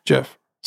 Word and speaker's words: Gef: By the way, I'm going to stop here and mention that Jeff is spelled G-E-F. – Hmm. – And I kept thinking that it was called Geff, but on the Wikipedia article, you know how they always put the pronunciations? – Mm-hmm. – The pronunciation is J-E-F. – I like that. – Jeff. So it's fancy. – Jeff spelled like Geff.